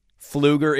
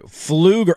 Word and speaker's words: Pfluger: Pfluger. Oh, it's spelled like Pflugrad. It starts with a P. It's a PF spelling.